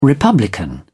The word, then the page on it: republican: British English